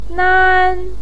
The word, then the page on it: nan1: pinyin